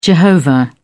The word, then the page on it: Jehovah: British English